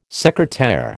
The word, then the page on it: secretaire: American English